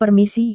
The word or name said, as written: permisi